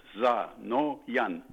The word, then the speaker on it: Zanoyan: Actually, it's simple. Three syllables. Za, no, yan.